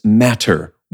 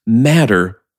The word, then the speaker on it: matter: The actor playing Polonius here clearly says matter with the full force of that double T. This is something you'll never hear stateside, where the word is pronounced matter as though there are two D's.